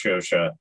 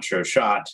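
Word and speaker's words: Chauchat: First of all, I just realized it's pronounced Shosha and not Shoshot, which is what I've been calling it for decades.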